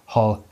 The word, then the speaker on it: Hall: My last name's Hall, right? So people, what's your last name? I go Hall, H-A-L-L. Well, what I really do is I go H-A-L-L, and I try and get away with that, but I go H-A-L-L, Hall.